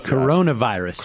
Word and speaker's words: coronavirus: Something, I don't know how to pronounce it. – Coronavirus. – Corona? – Yeah. – Really? – Yeah, not carnivorous.